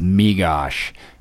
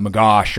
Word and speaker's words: Megosh: And so many people mispronounce it. You know, they call it magosh or magoosh or you know something else.